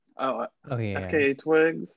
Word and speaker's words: FKA Twigs: Yeah, FKA Twigs.